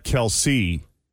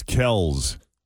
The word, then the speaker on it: Kelce: Like K-E-L-S, he said, right? Kels? – Yeah. – Or Kiels? – Yeah, it's not Kelce. It's Kels. Kels. – Well, then why is it spelled K-E-L-C-E?